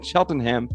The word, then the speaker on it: Cheltenham: Because in England, I learned you don't pronounce the N-ham. It should be Tottenham and Cheltenham. But yeah. Tottenham taught me that's just pronounced num. So you say Tottenham, Cheltenham.